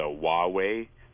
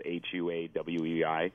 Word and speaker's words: Huawei: And so the one that I ended up going with is the Huawei. Is how it's pronounced, however it's spelled, Huawei.